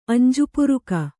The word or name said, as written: ಅಂಜುಪುರುಕಿ